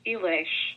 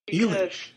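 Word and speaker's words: Illes: It's a Hungarian name, and the Hungarian alphabet is different. Mm-hmm. From the standard European alphabet, it's actually pronounced Elish. – Elish.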